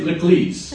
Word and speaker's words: l'église: In the Latin languages, l'église, right? And so what we find out, did I say that correctly? Iris, did I say l'église? – Uh-huh. – Is that correct? – It sounds good.